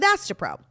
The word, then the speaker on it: Astepro: Faster pro meant to rhyme with Astapro? – Yeah, they've got their rhyming on point.